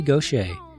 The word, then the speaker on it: Gauthier: For the rest of the show, I'm very pleased to bring you an interview with Mary Gaucher. Now, she was raised in Tripadeau, Louisiana, and Gaucher is a very Louisiana name. It's spelled G-A-U-T-H-I-E-R, and her album covers are quick to give the pronunciation.